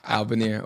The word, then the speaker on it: Albaner: And it's pronounced Albaner. – Albaner. – Not Albaner, what other names you've heard? – Albaner. Albaner. I'm not trying to give people ammo. Albaner or Al is cool.